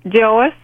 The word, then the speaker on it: Joyce: Joyce. J-O-Y-C-E.